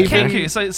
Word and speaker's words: Kenku: Kenko, is that how it's pronounced? – Yeah, Kenko. So like the birds that can only imitate shit?